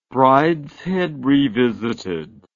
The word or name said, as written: Brideshead Revisited